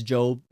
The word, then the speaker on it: Job: This is not pronounced Job if you're seeing it on screen. It's Job, Middle Eastern pronunciation, Hebraic pronunciation. Chapter 34, verse 21. And really, in Hebrew, it's Eov. But it comes over into English, transliterated as Job.